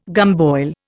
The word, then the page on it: gumboil: Indian